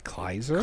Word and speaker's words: Kleiser: And our director, Randall Kleiser, am I saying it wrong? – I would say it's Kleiser. – Kleiser. – Let's go with Kleiser.